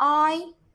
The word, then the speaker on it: describe: Describe. Describe. Describe. 啊，describe 呢，它是动词，表示描述。我们注意它的这个发音哈，重音符号呢在后面啊，前面这个 d 呢，它是轻一点的读的。